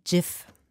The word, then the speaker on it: GIF: Wert legte Wilhite auf die korrekte englische Aussprache seiner Erfindung mit weichem G, also G wie GIF.